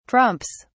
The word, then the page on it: trumps: British English